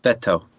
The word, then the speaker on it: Beto: How do you pronounce Beto? Beto? – It's Beto. – That sounds wrong. – that's how you say it, though.